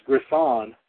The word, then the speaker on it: Griffon: You're known as New, I guess it's pronounced Griffon. It's not Griffin because that's with I-N. This is spelled with O-N.